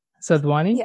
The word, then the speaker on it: Sadhwani: Sadhwani? – Yes.